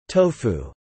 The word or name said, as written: Tofu